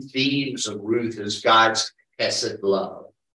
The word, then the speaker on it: hesed: The Jew who would read this book reads of God's, the Hebrew word for it is transliterated H-E-S-E-D. And you say it like you're clearing your throat.